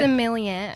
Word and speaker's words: sommelier: Sommelier. – Sommelier.